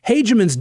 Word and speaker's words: Hageman: Most recent polling has Liz Cheney down more than 20 points to the likely Republican winner, and that is Harriet Hageman. I think I said Hageman. I think it's Hageman. I've heard both pronunciations, but I think most news outlets are saying Hageman. So let's go with Hageman.